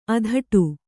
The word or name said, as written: ಅಧಟು